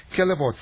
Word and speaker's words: Callebaut: Kellebot, if I pronounce correctly, Kellebot, C-A-L-L-E-B-A-U-T, Kellebo, chocolates, is that permissible?